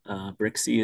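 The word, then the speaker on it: Brzycki: Brixey is how you pronounce it.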